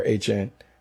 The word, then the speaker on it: Etienne: Alvin Kamara is still slated to be back, and he's joined by Travis Etienne, or HN. He changed his pronunciation.